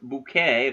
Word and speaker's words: Bucket: Hyacinth Bucket, pronounced bouquet according to Hyacinth,